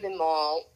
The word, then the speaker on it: Imaal: And then Imaal, Imaal, is pronounced like, where would you get one? Well, you would get one at a mall if they ever sold them there, which they don't, but it's pronounced sort of the same way.